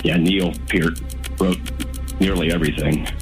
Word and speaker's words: Peart: It's pronounced Peart, by the way, too. Not Burton. – Did you see the part in I Love You, Man, where he corrects the, I've had people call me on it. I'm like, oh no, I have the audio to prove it. It is pronounced Peart, not Pert.